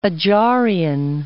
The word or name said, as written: Adzharian